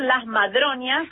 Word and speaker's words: Madroñas: es taller Las Madroñas. – Ah, Las Madroñas, madre. Sí, perdón, lo dije mal, Las Madroñas. – No, no hay problema. Es como madre y doña.